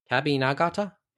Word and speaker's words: Kabi Nagata: Kabi Nagata? – Yep.